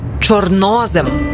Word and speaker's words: чорнозем: Чорнозем чи чорнозем? Як правильно? Наголос на друге О. Чорнозем.